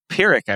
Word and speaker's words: Pyrrhic: Pyrrhic, I believe, is how you say it. Let me... – Yeah, P-Y-R-R.